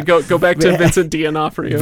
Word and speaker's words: D'Onofrio: Did just say D'Onofrio? – D'Onofrio. – Okay. – That is how he pronounces his name.